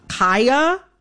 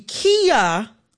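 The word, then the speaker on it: Khia: I am going to, in this conversation, pronounce Kaya, the rapper, with the long I. Kaya, okay? My neck, my back, for those who need a refresher. Kaya, okay? The Kia Asylum is a mispronunciation of her name.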